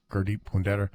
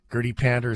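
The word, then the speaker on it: Gurdeep Pandher: I think Gurdeep Punder is probably the best. Now, I can't roll my R's, but I try to get, you know, Gurdeep Punder. That's how he pronounces his name properly. Gurdeep Pander is sort of the English pronunciation.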